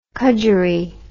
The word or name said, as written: cudgerie